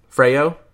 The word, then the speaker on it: Freo: Freyo. F-R-E-O. It's like Oreo with an F.